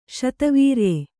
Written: ಶತವೀರ್ಯೆ

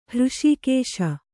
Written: ಹೃಷೀಕೇಶ

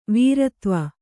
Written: ವೀರತ್ವ

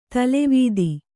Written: ತಲೆವೀದಿ

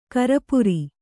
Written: ಕರಪುರಿ

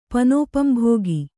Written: ಪನೋಪಂಭೋಗಿ

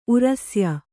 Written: ಉರಸ್ಯ

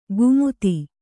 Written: ಗುಮುತಿ

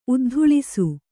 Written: ಉದ್ಧೂಳಿಸು

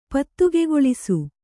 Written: ಪತ್ತುಗೆಗೊಳಿಸು

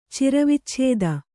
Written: ಚಿರವಿಚ್ಛೇದ

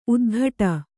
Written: ಉತ್ಕಟ

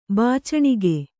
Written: ಬಾಚಣಿಗೆ

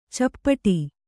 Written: ಚಪ್ಪಟ್ಟೆ

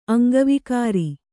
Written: ಅಂಗವಿಕಾರ

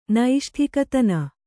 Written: ನೈಷ್ಠಿಕತನ

